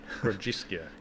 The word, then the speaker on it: Grodziskie: Grodziskie.